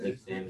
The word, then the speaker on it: Nick Pham: Vampboyz in the building, got Nick Pham in the building.